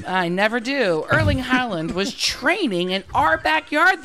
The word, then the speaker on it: Erling Haaland: She showed me a video of Erling Haaland.